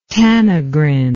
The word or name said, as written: tanagrine